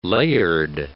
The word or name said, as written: layered